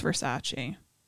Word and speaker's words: Versace: It's beautiful, but it's pronounced Versace.